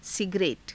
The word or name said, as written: sigare_ta